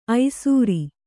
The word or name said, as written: ಐಸೂರಿ